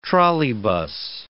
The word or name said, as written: trolleybus